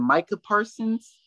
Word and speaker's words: Micah Parsons: I like the, is it Micah Parsons, if I'm correct?